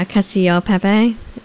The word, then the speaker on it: cacio e pepe: Yeah, and I got, like, cacio e pepe. How do you pronounce that? – Oh, yeah, cacio pepe. – Yeah, like that.